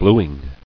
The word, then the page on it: Bluing: blu·ing